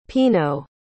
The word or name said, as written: pinot